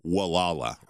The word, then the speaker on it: Gualala: Well, it's Gualala, but if you're a local, how they pronounce it and they get offended, it's Wallala.